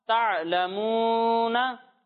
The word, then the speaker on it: تعلمون: تا عین زبر تا, لام زبر لا, تا لا میم واو پیش مو, تا لمو نون زبر نا, تا لمونا.